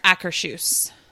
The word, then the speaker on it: Akershus: We had a breakfast at Akershus. – Okay. – Is that right? Did I say that right? – Yeah. I think it's Akershus.